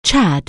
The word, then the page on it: Chad: anglais